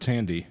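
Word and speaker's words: Thandie: It's pronounced Thandi.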